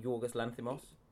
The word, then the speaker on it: Yorgos Lanthimos: And I read recently that you picked up um, a film in Cannes, The Lobster by Yorgos Lanthimos. Have I pronounced that correct? Yorgos Lanthimos? – Perfect.